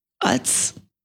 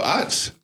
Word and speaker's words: Utz: Utz. How do you say it? Oh, Utz.